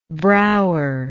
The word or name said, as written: Brouwer